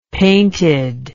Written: painted

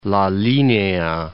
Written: La Linea

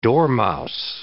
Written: dormouse

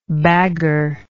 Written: bagger

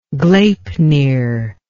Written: gleipnir

